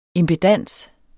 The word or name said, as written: impedans